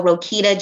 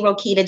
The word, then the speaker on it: Roquita: My name is spelled R-O-Q-U-I-T-A, so Rokita Johnson. As far as I know, I'm the only Rokita Johnson on there, so that's the good part.